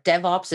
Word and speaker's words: DevOps: Am I pronouncing that right? – You are. DevOps is the right pronunciation.